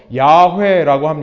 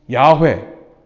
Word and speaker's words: 야훼: 이 여호와라는 발음이 정확하지 않다는 것을 많은 학자들이 얘기하고 야훼 혹은 야외로 읽어야 된다라고 얘기를 하죠.